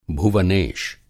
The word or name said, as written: भुवनेश